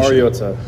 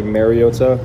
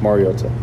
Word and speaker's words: Mariota: Mariota. I think it's been said over the years like Mariota, which is incorrect, and it's pronounced Mariota.